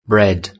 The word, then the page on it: bread: British English